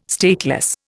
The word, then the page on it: stateless: Indian